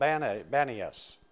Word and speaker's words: Banias: Now, so the name, because in Arabic, there's no letter P in Arabic, and so the name of the area became called, they substituted a B for the P and called Banias. And so that's the way it's pronounced.